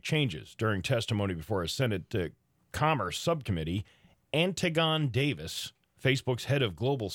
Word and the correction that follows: Antigone: No, I think it's pronounced Antigone.